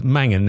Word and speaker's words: Mangan: And today we're going to interview David Mangan.